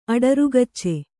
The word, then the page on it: ಅಡರುಗಚ್ಚೆ: aḍarugacce